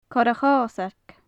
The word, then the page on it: corra-chosag: gaelic